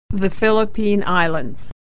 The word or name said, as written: the philippines